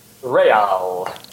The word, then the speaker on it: Real: Is it pronounced Real Madrid or Real Madrid? – Real, as in royal.